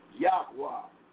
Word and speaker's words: Yahweh: And like I said again, the series is part number 30, the due order of YHWH, proper pronunciation, Yahweh,